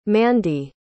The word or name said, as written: mandy